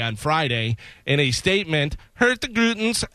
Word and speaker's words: Hurtigruten: The staff on Norwegian Cruise Liner, now I want to get this proper, Hurtigruten's ship. Yeah, that's right. That's how you pronounce it.